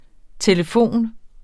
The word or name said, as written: telefon